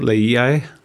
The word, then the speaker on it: Laie: Laie.